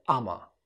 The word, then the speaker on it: I'ma: دي نطقها كده أما.